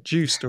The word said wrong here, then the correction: Yoast: or is it Yoast? – Yoast. Oh, okay. So Yoast is the pronunciation.